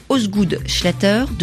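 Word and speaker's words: Osgood-Schlatter: Aujourd'hui, vous nous parlez d'une maladie, maladie de Osgood Schlatter.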